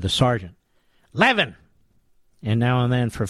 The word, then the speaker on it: Levin: No, the truth is, in Philadelphia, at least where I grew up, L-E-V-I-N is pronounced Levin. Much of the rest of the country, it's pronounced Levin.